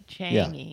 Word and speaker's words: Changi: It's like rangy, but Changi.